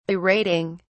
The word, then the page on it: berating: British English